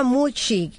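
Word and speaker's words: Muchi (虫): Y entonces en japonés se pronuncia Muchi, que es el mismo que el término nipón, que significa bicho.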